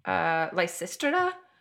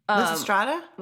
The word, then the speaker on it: Lysistrata: Lysistrata? – Lysistrata? – Lysistrata. Yeah, either way. Lysistrata, Lysistrata. I looked up how to pronounce that. It's either one, but yes.